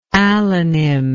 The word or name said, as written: allonym